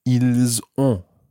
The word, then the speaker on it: ils ont: And then they have is ils ont.